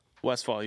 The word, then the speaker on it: Westphal: Did I say it right? – Westphal, you said it right, yeah.